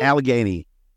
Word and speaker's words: Allegheny: at I can't say it Allegheny. Is that how you say it? Allegheny. Allegheny. – Allegheny. At Allegheny College.